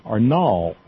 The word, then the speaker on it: Nall: Loretta Nall, I believe it's pronounced, N-A-L-L, Nall, will be on Bob's show tomorrow night as a guest.